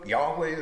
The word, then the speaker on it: Yahweh: And really what is being said is this, that Israel's God, Jehovah, Yahweh, as the Hebrew scholars say we should be pronouncing it these days, they, I suspect, don't really know.